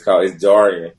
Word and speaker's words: Jarian: And I laugh when people call me Jerry because that's like the wrong pronunciation of it. It's Jarian.